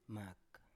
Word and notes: mak: Biak

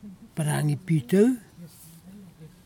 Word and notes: paragi pitov: kwamera

